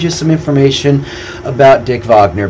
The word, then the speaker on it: Wagner: spelled Wagner, W-A-G-N-E-R, but it's actually pronounced Wagner because he's German.